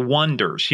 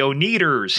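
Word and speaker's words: Oneders: But the band's name was spelled O-N-E-D-E-R for the Wonders, you know, the Wonders. And everybody kept pronouncing it the O-Neaters. Here they are, the O-Neaters. The Wonders.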